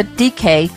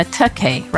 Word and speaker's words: Takei: Like, he's not going to DK. He's going to Takei, right?